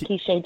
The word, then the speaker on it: Keeshae: Is it Keeshae? – Yes, it's Keeshae Jacobs.